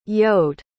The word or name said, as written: yoad